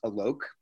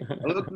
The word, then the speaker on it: Alok: I just wanted to let you know, my name is pronounced Alok. It rhymes with Alok.